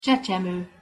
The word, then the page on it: csecsemo: Hungarian